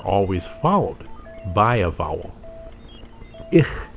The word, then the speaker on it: 'IH: and our word is ich, spelled apostrophe capital I capital H, ich, beautiful. This letter is pronounced as a glottal stop. It's a brief catch.